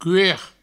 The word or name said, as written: cuer